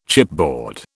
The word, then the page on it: chipboard: British English